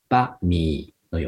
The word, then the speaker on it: バミー: これはタイ語ではバミーというふうに言います。バミー。はい。これはバの後のミーをちょっと低い成長で言うっていうのが発音のポイントですね。バミーのような感じですね。